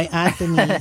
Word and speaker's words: Anthonie: My Anthony is spelled as A-N-T-H-O-N-I-E.